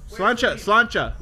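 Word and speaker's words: Sláinte: Slancha. That's how you say it.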